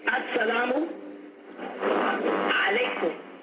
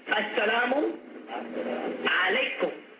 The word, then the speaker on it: As-salamu alaykum: Say, As-salamu alaykum. As-salamu alaykum.